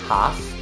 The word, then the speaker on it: half: Half can be pronounced in British English in a more open vowel sound, like half. And in American English, normally it's pronounced half. Notice that you don't pronounce the L in both varieties, British and American.